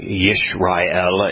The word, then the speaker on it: Yisrael: Yeah, because it's actually Yishrael. It's the proper pronunciation is with the Y.